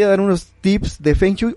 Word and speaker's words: Feng Shui: Tips de Feng Shui. Este, que la pronunciación debería ser Feng Shui, ¿no? – Sí, es, es feng, shui, Muy bien.